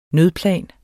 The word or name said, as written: nødplan